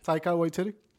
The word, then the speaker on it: Waititi: Taika Waititi.